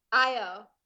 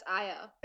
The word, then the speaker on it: Io: Or Lo, L-O. – Io. That's Io. – It's got an L here. I swear to God, it's got an L. I'm going to screenshot this. Io.